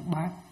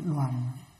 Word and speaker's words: đoàn: Chữ này đừng có đọc là chữ bát, nên đọc là chữ đoàn.